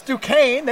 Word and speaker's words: Duquesne: Watching that game didn't know how to pronounce Duquesne. Everybody thought it was Duquesne. And it's Duquesne.